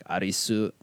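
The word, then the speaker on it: Arisu (アリス): That's just how it's pronounced in Japanese, like, because they pronounce every syllable, so it's like Arisu, like that.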